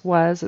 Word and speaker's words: was: So was, it's spelled with an S, but we say it with a Z, was an odd choice.